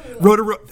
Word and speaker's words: Rotorua: Is I was in, I can't even remember what it was, Rotorua or Rotunga or whatever, wherever it is. Rotorua. I knew I said that wrong.